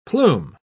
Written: plume

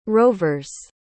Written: rovers

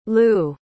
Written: Lugh

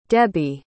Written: debby

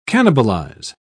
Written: cannibalize

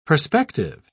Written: perspective

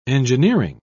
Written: engineering

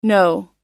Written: noh

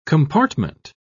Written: compartment

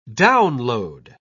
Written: download

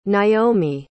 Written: Nyomi

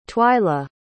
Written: twyla

